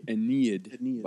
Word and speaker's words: Aeneid: Is that how it's pronounced? – The Aeneid by Virgil.